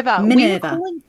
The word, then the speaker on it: Minerva: I hope I'm pronouncing that right. Maverna. – Minerva. We were calling it the wrong thing for a while. We have to confess. – Minerva. – We're also very Australian, so we've never heard a British person say it as every day on their back. – It's spelt Minerva, and it might be our Australian accents, but we interpret that as Minerva. – But I think we might be a bit heavy on the middle vowel.